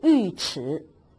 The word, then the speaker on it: 尉迟: 唐尉迟恭在此地，这个尉要读尉，如果单独的尉当姓的时候就读成尉，复姓那就读尉迟，所以尉迟恭他的字叫敬德。